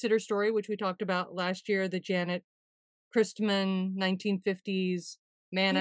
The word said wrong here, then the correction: Jeanette: Yeah, I just saw a little documentary on that, and it's actually pronounced Jeanette. I have to apologize that I continually mispronounced it a year ago.